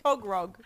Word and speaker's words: Tögrögs: Tögrögs. Pronounced T, O with the two little dots above it, G, and then R, O with the two little dots above it, G. Tögrögs.